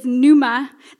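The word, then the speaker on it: pneuma (πνεῦμα): In the New Testament, the word spirit is pneuma. The P is silent there.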